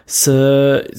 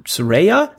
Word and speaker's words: Saraya: Dann war es Zeit für Saraya, hätte ich immer gesagt, aber Saraya wird es nicht pronounced. Saraya ist es, genau.